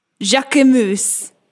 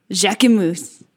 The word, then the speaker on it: Jacquemus: I'm looking up how to say his name. We're going to get to the bottom of this. Jacquemus. – Jacquemus? – Jacquemus. – Jacquemus. Okay, there we go.